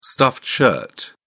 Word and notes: stuffed shirt: British English

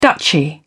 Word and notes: duchy: British English

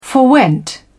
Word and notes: forwent: British English

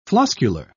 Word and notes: floscular: British English

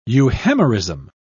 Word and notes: euhemerism: British English

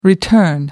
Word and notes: return: American English

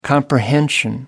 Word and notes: comprehension: American English

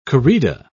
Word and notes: corrida: British English

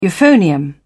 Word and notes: euphonium: British English

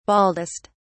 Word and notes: baldest: British English

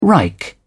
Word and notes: reich: British English

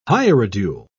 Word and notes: hierodule: British English

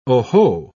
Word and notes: oho: British English

